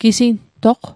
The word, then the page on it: gisintoq: Mi'gmaq